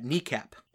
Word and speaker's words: NEACP: It used to be called the National Emergency Airborne Command Post, or NEACP, and they pronounce that kneecap.